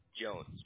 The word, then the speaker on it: Jones: And Clarence, just for account purposes, your first name, do you spell that C-L-A-R-E-N-C-E? – Yes, sir. – Okay, perfect. And what's your last name, Clarence? – Jones, J-O-N-E-S, right? – Yes.